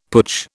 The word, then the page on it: putsch: British English